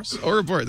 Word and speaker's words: Ouroboros: Ouroboros.